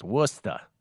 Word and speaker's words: Worcester: Remember, Ben, it's pronounced Worcester. Okay.